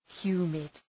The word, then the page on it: humid: ‘hju:mıd